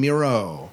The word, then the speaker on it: Mierau: In our first promo, we're going to play uh, a promo from John Mierau. Rhymes with hero, remember?